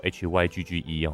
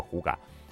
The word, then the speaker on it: hygge: 好，那什么是呼嘎呢？它其实写作 HYGGE 哈，它不叫 Hygge，它在丹麦那边念作呼嘎。